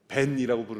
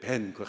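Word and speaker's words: 벤이라고 (בן): 왜냐하면 히브리어로 아들이라고 할 때는 벤이라고 부르는데 우리가 운전할 때 벤, 그 같은 발음입니다.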